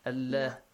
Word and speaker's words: Allah: If there is a kasra before the word Allah, the word Allah is pronounced lightly. Allah.